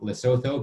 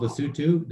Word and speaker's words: Lesotho: So a lot of people see the name Lesotho and they think Lesotho because that's how it's spelled. So it's the place is called Lesotho, the people are called Basutu, and the language is called Sesotho.